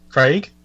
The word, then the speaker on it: Craig: It's pronounced Craig.